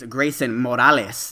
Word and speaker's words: Greysun Morales: Today is actually a special episode because we have a special guest today, and that is Greysun Morales. Did I say that correctly, Greysun? – Yes, that is correct, just like Miles Morales.